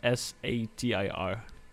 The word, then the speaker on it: Satir: Uh, S A T I R.